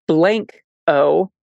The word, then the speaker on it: Blanco: It's said locally as Blanco, like gringos might say it, and not Blanco, as like you would pronounce it in Spanish.